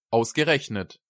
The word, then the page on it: ausgerechnet: German